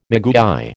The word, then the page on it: maguey: American English